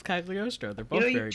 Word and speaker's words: Cagliostro: Listener, if you don't have Netflix to watch Castle of Cagliostro, message me on our Discord. – That's how you say it.